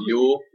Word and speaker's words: Youell: It's spelled – well, no, it's spelled like – Y-O-U-E-L-L. It's like Yule. – Yule.